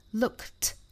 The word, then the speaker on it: looked: 我们看到这两个单词 look 和 promise 尾部都是清辅音，分别是 k 和 s，所以它们要变成过去式的话呢，应该读成 looked 和 promised，这就是清清浊浊了。